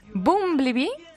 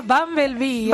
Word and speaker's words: Bumblebee: Bueno, vamos a empezar con Bumblebee, que no sé si lo he dicho bien. – No. – No lo he dicho bien, vale. – Se llama la película Bumblebee. – Bumblebee, es lo mismo.